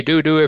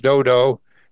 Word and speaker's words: Dodo: You can say Dudu if you want, but it's actually pronounced Dodo.